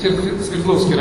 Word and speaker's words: Свердловский: Потом я поехал в Свердловский район, да, так, ударение правильно стало, да?